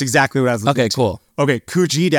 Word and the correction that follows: Kuljeet: asked this question. Uh, I hope I'm pronouncing that correctly. It's uh, Kuljeet. Kuljeet. Oh, I'm sorry. Okay, yes, Kuljeet.